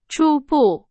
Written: 初步

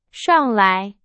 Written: 上来

